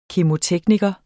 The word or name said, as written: KEMOTEKNIKERE